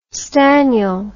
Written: staniel

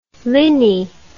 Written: linney

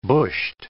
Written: bushed

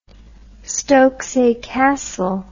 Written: stokesay castle

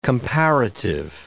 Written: comparative

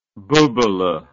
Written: bubaleh

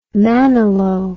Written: manilow

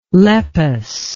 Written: lepus

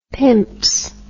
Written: pimps

